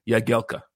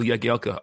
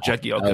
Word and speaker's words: Jagielka: And he goes, it's a properly pronounced Phil Jagielka. – So did you start? – Yeah, silent J. So I always called him Phil Jagielka after that, not Jagielka. – Oh, wow. – I'd always called him Jagielka prior to that, but it's Phil Jagielka.